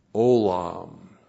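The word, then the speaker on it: Olam (עולם): And then olam. Ayin, long O, L, A, M. Olam.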